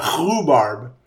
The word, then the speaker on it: rhubarb: with a silent H. A rhubarb.